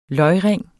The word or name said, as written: løgring